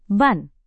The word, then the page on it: ban: indonesian